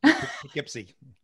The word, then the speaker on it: Poughkeepsie: Poughkeepsie. – Thank you, Poughkeepsie.